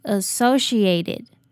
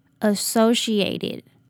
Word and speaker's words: associated: 接着后面的 ae 发 a 的音，那这里的 t 我们会弱化它 ed 结尾，就是念作 eat，并且要把重音放在第二个音节，念作 associated associated。